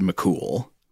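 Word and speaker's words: Mac Cumhaill: But the other one is spelled more like Fionn, and then the last name is Mac, C-U-M-H-A-I-L or H-A-I-L-L, which from what I understand, I know pronunciation a lot of these Irish words is notoriously tricky for English speakers like us. But I think that is also pronounced pretty much just McCool.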